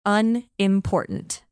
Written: unimportant

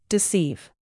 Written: deceive

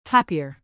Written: tapir

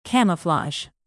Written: camouflage

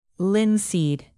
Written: linseed

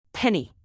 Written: penny

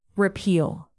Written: repeal

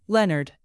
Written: leonard

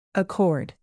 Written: accord

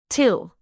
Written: till

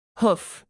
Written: hoof

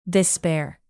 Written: despair

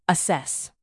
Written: assess

